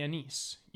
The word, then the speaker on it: anise: So the seed of the anise used in cooking and herbal medicine. So yeah, it's anise. Anise is made with aniseed. – Hmm. – And you pronounced it correctly.